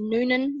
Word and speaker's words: Nunan: Holly Noonan. Noonan is spelled N-U-N-A-N.